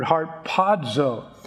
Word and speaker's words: harpazo: Now, I've already explained that this is the Greek word harpazo. It's spelled H-A-R-P-A-Z-O.